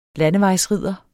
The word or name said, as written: landevejsridder